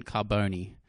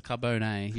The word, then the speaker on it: Carbone: And Carbone. – How did I pronounce it? – Carbone.